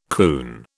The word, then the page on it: coon: British English